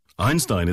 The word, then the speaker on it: Einstein: 네, 영어식 발음은 아인슈타인이에요. 아인슈타인 아니고요. – Right, very thin S, Stein, 네. Einstein.